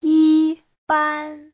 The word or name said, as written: yi1ban1